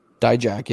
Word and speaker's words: Dijak: I don't know if I said that right or not. Dijak, yes.